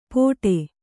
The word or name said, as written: ಪೊಡೆ